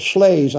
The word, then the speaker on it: Shlaes: Now, Miss Shales uh, Shlaes, I believe.